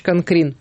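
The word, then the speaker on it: Канкрин: Это Георг Людвиг, он же Егор Францевич Конкрин. Так, во всяком случае, официально ставится ударение.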